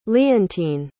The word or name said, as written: leontyne